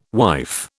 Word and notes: wife: British English

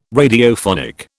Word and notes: Radiophonic: British English

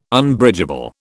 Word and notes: Unbridgeable: British English

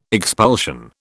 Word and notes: expulsion: British English

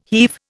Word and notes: heave: Indian

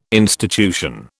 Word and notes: institution: British English

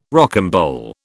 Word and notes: rocambole: British English

